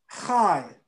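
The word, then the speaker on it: chai (חַי): Or you can say chai, which is spelled in Hebrew chet and yod. Chai.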